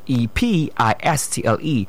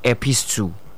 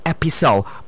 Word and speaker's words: epistle: Another one is epistle. That word is not epistle. It's an epistle because the t is silent.